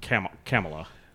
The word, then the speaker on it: Kamala: And I'm trying to say her name correctly because it's not Kamala, it's Kamala.